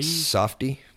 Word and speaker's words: Safdie: I'm not sure we'll get the pronunciation right, but Sadafi or Sadi? – Softie. – Softie? – Yeah, S-A-F-D-I-E.